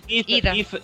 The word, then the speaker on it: Heath Ledger: Heath Ledger, giusto? – Heath Ledger.